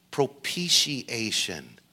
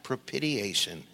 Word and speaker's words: propitiation: It's pronounced propitiation, not propitiation."